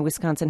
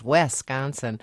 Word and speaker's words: Wisconsin: Yeah, and that is the name of the state is mispronounced too by out of staters, mainly the sportscasters, Wisconsin, and then you'll correct. I mean, I see people correcting. You know, no, it's not Wes, it's Wis, and yet it continues.